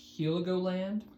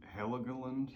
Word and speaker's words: Heligoland: This was really on my mind going through today's album, Heligoland by Massive Attack. – Mm-hmm. – Yeah, the pronunciation I'm still trying to get a hang of. I think it's like Heligoland. – Heligoland.